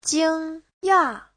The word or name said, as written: jing1ya4